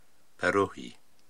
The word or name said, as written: Perruhi